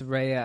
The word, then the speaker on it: Rhea: Ray Jones. – Rhea Butcher. – I know. It's Rhea. I keep mispronouncing it. I've always said, for some reason, I say Ray. – Ray. – But it's Rhea.